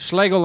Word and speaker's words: Schlegel: Schlegel like bagel.